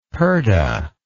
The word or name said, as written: pardah